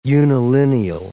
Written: unilineal

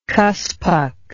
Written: kuspuk

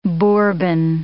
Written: bourbon

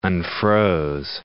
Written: unfroze

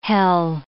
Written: hell

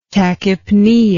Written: tachypnea

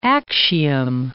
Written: Actium

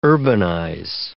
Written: urbanize

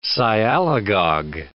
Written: sialagogue

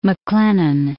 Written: MacLennan

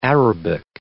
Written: Arabic

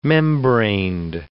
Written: membraned

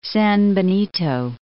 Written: San Benito

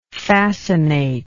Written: fascinative

